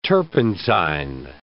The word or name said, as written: turpentine